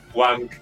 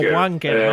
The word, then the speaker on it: Wanker: Wanker. – O Wanker, ¿no? Bueno, claro, pero lo pronuncian en plan como el acento inglés que es Winecar.